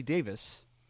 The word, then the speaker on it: Davies: Davis, which is spelled Davies, but it's pronounced Davis because those British.